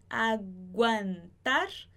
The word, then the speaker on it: aguantar: Analgésico, depois aqui aguantar, aqui a gente vai pronunciar o U porque é G-U-A, então aguantar. Depois quejas,